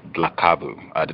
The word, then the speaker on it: Dlakavu: Context of white supremacy, again, joining us live from South Africa, our guest, Ms. Simamkele Dlakavu.